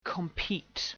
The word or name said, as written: compete